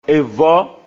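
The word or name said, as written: Èvɔ̆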